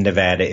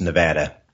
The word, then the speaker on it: Nevada: If you live in Nevada, it's pronounced Nevada. Everywhere else in the world, it's Nevada.